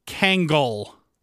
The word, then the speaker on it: Kangol: There is a missing syllable in there that I absolutely needed. Uh, it is Kangol. – uh, Kangol. That's it.